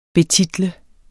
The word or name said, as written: betitle